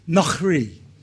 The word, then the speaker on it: nochri: And the word for today is pronounced nochri. So it's re, e, nochri.